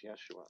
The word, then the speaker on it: Yeshua: Am I saying that right, Yeshua or Yeshua? – I think strictly speaking, it's Yeshua.